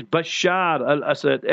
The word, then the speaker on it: Bashar al-Assad: Our this English and people in the Western press, they can't pronounce properly. So Mosul, they will write Mosul like that, you know. So they must pronounce it. The name is Bashar al-Assad.